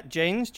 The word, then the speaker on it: Jaynes: Yeah, Jaynes, J-A-Y, N for November, E-S. – Okay. – I always say that because a lot of people say James.